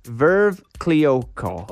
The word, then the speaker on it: Veuve Clicquot: So it's a giant bottle of, it's pronounced Veuve Clicquot.